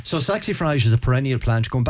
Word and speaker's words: Saxifrage: Okay, Saxifrage, have I pronounced that right? – Saxifrage, yeah.